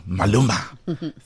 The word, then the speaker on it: Maluma: He currently has Malume. – No, Maluma. See, sorry, because my abuela. Was Latino, I know how to say these things.